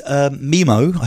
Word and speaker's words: MIMO: It uses MIMO, I think is actually the correct way of pronouncing it, not your...